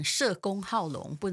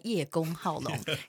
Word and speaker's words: 叶公好龙: 哎，那个叫做什么啊、呃？我老我小孩说要念成社公好龙，不能念叶公好龙。